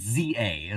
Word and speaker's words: ZA: You really want me to say Z to It's ZA is apparently how it's said.